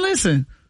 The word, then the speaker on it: listen: Listen is another one where the English words has a silent letter. – Silent and listen. – T. Listen. You don't hit no T in listen.